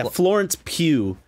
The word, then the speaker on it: Florence Pugh: Yeah, Florence Pugh.